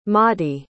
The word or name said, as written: Maudie